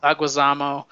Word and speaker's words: Leguizamo: Leguizamo.